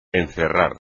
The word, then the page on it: encerrar: espagnol